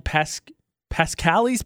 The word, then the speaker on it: Pasqually: But if you order from a place called Pascali's Pizza, I believe is how it's pronounced. P-A-S-Q-U-A-L-L-Y.